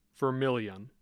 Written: Vermillion

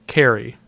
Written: Cary

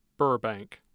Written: burbank

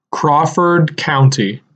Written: Crawford County

